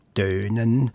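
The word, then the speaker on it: Thönen: Ich habe jetzt Tönen gesagt, wegen dem H. Wie würdet ihr es aussprechen? – Also ich denke, man hat im Schweizerdeutschen eigentlich nie aspiriert, selbst wenn es mit TH geschrieben worden ist, würde ich sagen, man hat vermutlich gesprochen Tönen und mit einem langen Ö im Berner Oberland eher Tönen.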